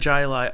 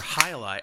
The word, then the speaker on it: Jai Alai: All right, let's get that Jai Alai opened, or High Alai. I keep saying Jai because it's spelled with a J and it just trips the weird response that my English-speaking brain has.